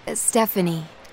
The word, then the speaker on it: Stefanie: Y-O-U-N-G. – Uh-huh. And what's your first name? – Stephanie. – How do you spell that? – S-T-E-F-A-N-I-E.